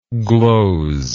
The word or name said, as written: glozingly